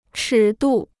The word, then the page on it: 尺度: chǐ dù